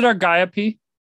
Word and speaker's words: Argiope: Is it Argiope? – That's how I say it.